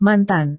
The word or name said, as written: mantan